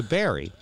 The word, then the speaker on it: Barre: So anyway, Lisa said, let's go to Barry. Barry, yeah. Vermont. It sounds like Barry, B-U-R-Y. It's spelled sounds B-A-R-R-E.